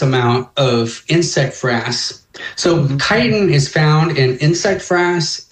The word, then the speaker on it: chitin: So a friend of mine, and I'm sure you've It's actually pronounced chitin. That's funny.